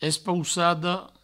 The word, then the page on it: espoussada: provençal; espoussado